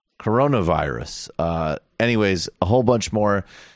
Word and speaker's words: coronavirus: Something, I don't know how to pronounce it. – Coronavirus. – Corona? – Yeah. – Really? – Yeah, not carnivorous.